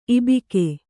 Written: ಇಬ್ಬಗೆ

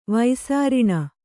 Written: ವೈಸಾರಿಣ

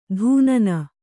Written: ಧೂನನ

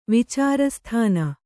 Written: ವಿಚಾರಸ್ಥಾನ

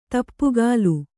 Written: ದಾಪುಗಾಲು

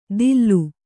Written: ದಿಲ್ಲು

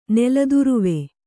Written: ನೆಲದುರುವೆ

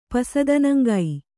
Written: ಪಸದನಂಗೈ

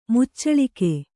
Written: ಮುಚ್ಚಳಿಕೆ

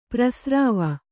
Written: ಪ್ರಸ್ರವ

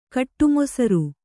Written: ಕಟ್ಟುಮೊಸರು

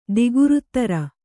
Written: ದಿಗುರುತ್ತರ

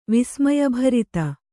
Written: ವಿಸ್ಮಯಭರಿತ

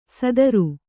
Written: ಸದರು

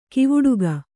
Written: ಕವಡುಗ

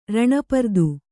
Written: ರಣಪರ್ದು

